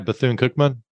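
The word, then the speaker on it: Bethune-Cookman: And I love how you're uh, working with Bethune Cookman Am I saying that right? Bethune Cookman? – Yes, you have it right.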